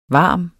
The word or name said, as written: varme